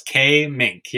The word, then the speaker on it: Kaemingk: It's Kaemingk. Yeah, Kaemingk.